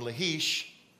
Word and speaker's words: Lachish: Then the king of Assyria sent his field commander with a large army from Lachish, that's how it's pronounced, to King Hezekiah at Jerusalem.